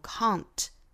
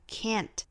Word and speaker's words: can't: 英式发法读 can't, can't, 美式发法呢读 can't, can't, 注意元音不一样。